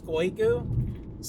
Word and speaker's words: Skoigu: We don't say the original, you know, language name, which is Skoigu.